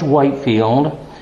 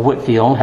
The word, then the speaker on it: Whitefield: was saved under George Whitefield, or Whitfield, however you prefer to pronounce it, and is credited with founding the Free Will Baptist denomination of churches in New England.